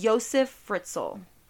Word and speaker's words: Josef Fritzl: his name is pronounced Josef Fritzl.